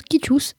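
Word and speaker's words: Citius: Eh bien oui, en latin, elle se prononce Quitus, Altius, Fortius.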